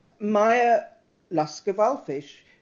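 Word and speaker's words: Maya Lasker-Wallfisch: Maya Lasker-Valfish. – Lasker, L-A-S-K-E-R. – Valfish, W-A-L-L-F, Freddy.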